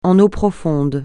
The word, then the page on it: en eau profonde: français